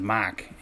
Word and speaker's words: Mark: but when you're from Massachusetts and you say your name is Mark to everybody else in the country, they think your name is Mike, and I say M-A-R-K, they say M-A-I-K, no, it's a thing that goes on forever.